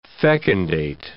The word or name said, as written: fecundate